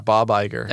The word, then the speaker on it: Iger: Yeah, Bob Iger.